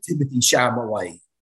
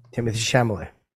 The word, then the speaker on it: Timothée Chalamet: I like Timothée Chalamet. Say his name. What's his name again? – Timothée Chalamet.